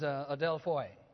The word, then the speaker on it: adelphoi: The word is adelphoi. Adelphoi. You might have, A-D-E-L-P-H-O-I phonetically. Adelphoi.